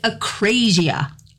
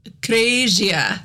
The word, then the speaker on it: akrasia: I picked acrasia. – Acrasia. – It's spelled A-K-R-A-S-I-A.